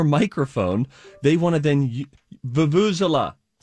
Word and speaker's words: Vuvuzela: Vuvuzela, Steve.